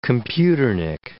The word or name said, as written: computernik